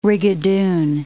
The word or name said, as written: rigadoon